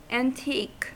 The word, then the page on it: antique: American English